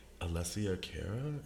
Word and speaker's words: Alessia Cara: I thought that was a decent tribute, and I really enjoyed Alessia Cara. Is that how I pronounce her name? – Yes.